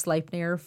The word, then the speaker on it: Sleipnir: And I'm actually very proud of that because it's spelled S-L-E-I-P-N-I-R. I was like, I bet you I'm pronouncing that wrong. And Ewan happened to be playing World of Warcraft with a Danish guy and asked him spelled it out and asked him how to pronounce it. And he was like, Sleipnir?